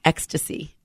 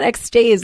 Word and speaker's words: Extase: but it's E-X-T-A-S-E, and it's pronounced ecstasy. – Oh, nice. I didn't know that. – I didn't know that either. I was like, so tell me about X-Days.